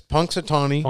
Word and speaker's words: Punxsutawney: It's Punxsutawney.